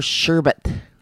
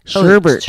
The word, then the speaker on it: sherbet: And then, of course, there's sherbet. – Sherbet. – Sherbert. – Oh, sherbert. – Mm-hmm. There's no Bert in sher.